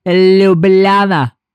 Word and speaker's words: Ljubljana: It's pronounced Ljubljana.